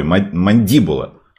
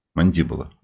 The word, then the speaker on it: мандибула: Ударение все-таки не на первый слог, а на второй. Мандибула. Мандибула.